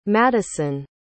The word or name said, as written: Maddyson